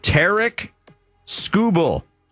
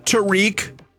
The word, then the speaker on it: Tarek Skubal: It's Tarek Skubal. Not Tariq. Not Tarek. Not Skubal. Not Skrubal.